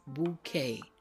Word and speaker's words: bouquet: The word is bouquet. Bouquet is a word that we use in the English language that has a French pronunciation. The Q and the U make a K sound.